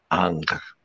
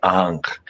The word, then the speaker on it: Ingres: And they dedicated an entire episode to a French artist called Jean-Auguste Dominique Ingres. It's a weird pronunciation of his name. It's I-N-G-R-E-S.